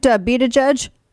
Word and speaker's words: Buttigieg: Buttigieg. Like be the judge. Right? No, be the judge. Pete Buttigieg.